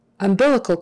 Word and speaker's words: umbilical: And of course the sound, O-U-N-D is oun, oun, sound. Umbilical cord, umbilical cord. The accent is on that second syllable. Umbilical, umbilical, bilical. C-A-L, that A-L is like an ul, ul. You wouldn't really say umbilical cord, umbilical cord, or umbilical, umbilical. Don't do that. Umbilical is not right.